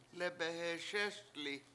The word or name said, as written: lebeheSESli7